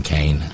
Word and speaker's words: Kean: Did I call him Tom Keene again? I meant to say Tom Kane. I know I'm mispronouncing it, but I'm forgivable for the fact that it's spelled K-E-A-N.